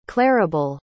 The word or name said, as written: clarabelle